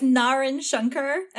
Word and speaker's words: Naren Shankar: I can tell you it's Naren Shankar, and that's just because that's an Indian name. – Naren Shankar? – Yeah.